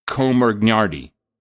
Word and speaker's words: Comirnaty: You sometimes pronounce the name of the vaccine as if it were Sherlock Holmes' rival, Moriarty. Comirnaty. May I suggest you pronounce it so it is nearly indistinguishable from your best woke impersonation of the word community. If you practice the pronunciation by referring to those who accepted the vaccine into the life as the Comirnaty community, then it sounds more like Cumanate, Cumanate. And you've nailed it.